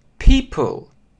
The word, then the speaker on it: people: В чем здесь сложность? В том, что, опять же, если не знать транскрипцию, то сложно догадаться, что вот эта комбинация букв произносится как «и». То есть многие как угодно говорят «people», как угодно, но никак не «и», а это чисто «и».